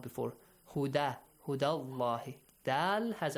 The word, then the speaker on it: Allah: The word Allah, the letter lam in the word Allah is pronounced heavily because of the...